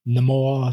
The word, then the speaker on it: Namor: Namor. How they pronounced it. – Namor, shit. – It's canon now, sir. – Who called him that in the movie besides himself? No one. – That's Namor. The only one that matters.